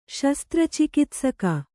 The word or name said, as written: ಶಸ್ತ್ರಚಿಕಿತ್ಸಕ